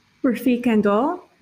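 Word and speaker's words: Refik Anadol: How do you say his name? – Rafik Andol? – Yes.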